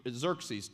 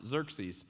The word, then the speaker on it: Xerxes: Xerxes, two X's, that's how you pronounce it, Xerxes.